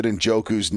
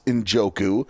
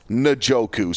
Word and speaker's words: Njoku: I always thought it was Njoku. And no, it is Njoku.